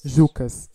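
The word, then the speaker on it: Juccas: E o artista é o Jucas. Se escreve J-U-C-C-A-S. E nessa música ele mistura o funk brasileiro com o tango. Jucas. Jucas, exato.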